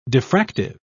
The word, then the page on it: diffractive: British English